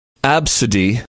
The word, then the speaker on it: Abcde: Topping the list, this name is pronounced Absidy, but it is spelled A-B-C-D-E.